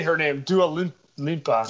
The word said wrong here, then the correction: Dua Lipa: Dua Lipa.